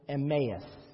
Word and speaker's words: Emmaus: That's how you pronounce it, Emmaus.